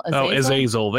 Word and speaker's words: Azazel: Azazel. They say it in the movie Azazel, so I just pronounce it that way.